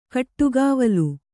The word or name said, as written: ಗಿಡಗಾವಲು